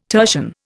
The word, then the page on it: tertian: Indian